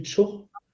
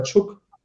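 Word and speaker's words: Czuch: Ich zweifle ja sogar selber dran, ob der Tschuch oder Tschuck ausgesprochen wird.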